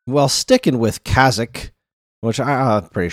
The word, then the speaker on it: Kazakh: K-A-Z-A-K-H. – Yeah. – I believe the H is silent because I don't think they want me to call it Kazakh H.